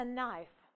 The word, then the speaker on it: knife: Ну, например, например, a knife, нож. A Knife. Нож. A knife. У нас при произношении последний будет звук F. A knife. Но в написании присутствует так называемая немая I.